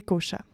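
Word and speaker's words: kośa: les cochas. Pour commencer, Cocha ça s'écrit K-O-S-A. Le S, il y a un petit accent dessus, donc il se prononce ch. donc, des fois, on va voir aussi que c'est écrit K-O-S-H-A.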